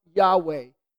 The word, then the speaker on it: Yahweh: The name was pronounced Yahweh.